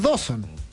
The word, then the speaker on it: Dawson: Si se escribe igual que la isla Dawson, es Dawson. No lo argentinicemos los apellidos ingleses, es ya.